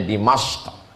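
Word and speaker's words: Dimashq (دمشق): Orang Arab menyebutnya Dimashq.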